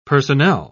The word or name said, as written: personnel